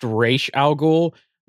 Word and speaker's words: Ra's al Ghul: It's pronounced Ra's al Ghul.